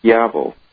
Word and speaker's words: Schiavo: First of all, the proper way to pronounce the name in Italian is Schiavo. – It means slave.